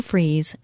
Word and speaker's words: Fries: It's Sue Freeze, spelled like fries.